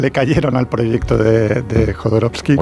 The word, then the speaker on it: Jodorowsky: iba a hacer Jodorowsky, que era un documental divertidísimo.